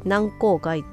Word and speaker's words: 軟口蓋: 柔らかい口の蓋」っていう漢字を書いて「軟口蓋」って読むんですがその下唇ほっぺたそれから軟口蓋これを動かしていくの全部筋肉によって成り立っているんですよね。